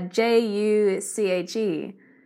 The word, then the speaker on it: Juche: This term, and I'm sure I'm not pronouncing it correctly, uh, uh J U C H E. Could you tell us what that is? – The way you say the word is juche. So it's a two syllabic word, juche.